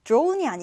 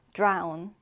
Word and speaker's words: drown: drone이 아니고 여러분, drown, drown입니다.